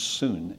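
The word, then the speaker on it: soon (σύν): Now, this word that we were looking at, with, pronounced soon in the Greek, is only used by the gospel writer John twice.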